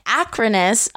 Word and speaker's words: Akranes: I am in Akranes, Iceland.